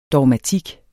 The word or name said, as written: dogmatik